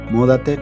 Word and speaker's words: Múdate: En Facebook estamos como Múdate con acento en la U, en Instagram como múdate.la.